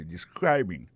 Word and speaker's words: describing: Ça nous ka wè là, ça nous constate là, nous ka constate que li a prononcé mot de describing. Accent tombe sou deuxième syllabe là.